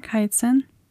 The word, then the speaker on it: Kaizen: Und Kaizen? Habe ich das richtig ausgesprochen? Kaizen? – Klingt gut.